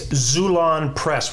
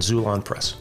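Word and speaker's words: Xulon: It's Zulon Press with an X, X-U-L-O-N, X-U-L-O-N, Zulon Press.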